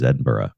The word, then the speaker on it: Edinburgh: Where you are at the moment geographically is Edinburgh, if I said that correctly at all. – Yes, that is correct.